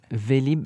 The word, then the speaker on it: Vélib: This is the what's the Vélib. – Name? – Vélib. – How do you spell that? – Vélib, V-E-L-I-B.